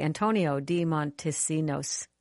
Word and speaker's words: Antonio de Montesinos: And I'm going to pray I say his name correctly, Antonio de Montesinos. – Yes, very good.